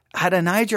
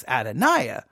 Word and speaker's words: Adonijah: Now his explanation is, well, because in Hebrew, the J would be silent. Therefore, in English, we should say it as Adoniah.